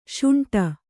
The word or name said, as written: ಶುಂಠಿ